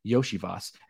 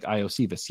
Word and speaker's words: Iosivas: You might say, hey, who the heck is Andre Yoshivas? And why is that how you pronounce his name? It looks like Iosivas.